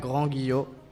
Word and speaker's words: Grand Guillot: Es Grand Guillot Milbaques. – Pero pronúncialo bien, pronúncialo bien. – A ver, en francés se pronuncia Grand Guillot.